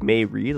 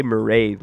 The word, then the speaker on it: Mairead: Like, her first name's spelled like May Reed, like M-A-I-R-E-A-D. But you say Marade, like parade. So, oh. like, you would read it in yeah. plain English, it would say May Reed, but it's Marade.